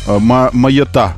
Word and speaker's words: Майотта: Как говорить правильно, Майотта или Майота? Майота.